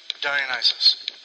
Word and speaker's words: Dionysus: Thank you, Dionysus." See, I pronounced it right for once.